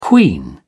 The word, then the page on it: queen: British English